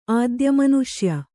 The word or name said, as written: ಆದ್ಯಮನುಷ್ಯ